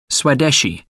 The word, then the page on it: swadeshi: American English